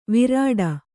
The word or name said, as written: ವಿರಾಟ